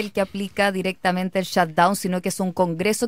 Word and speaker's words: shutdown: que se pronuncia shutdown.